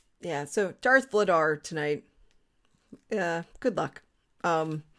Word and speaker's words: Vladar: And somebody uh, from Canada was like, Later. Flater. That's his name. Flater. I'm like, mm-mm, that is not how to say it.